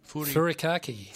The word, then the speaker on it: Furikake: How do you say this properly? Furake? – Furikake. – Furikake. There's that extra K.